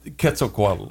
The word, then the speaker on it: Quetzalcoatl: And what they predicted was that in 2016, the feathered serpent of Aztec lore, known as Quetzalcoatl, would return. Yeah. How do you pronounce that again? – Quetzalcoatl.